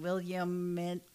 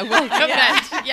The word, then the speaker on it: Willamette: Like it took me forever and a day to say Willamette without saying Williamette. – Yeah. Willamette. Yes. Because that's exactly how it should be.